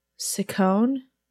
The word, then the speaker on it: Ciccone: I want to say Ciccone is how it would be pronounced. C-I-C-C-O-N-E. But yeah, Madonna for some reason is calling Jordan Cahill.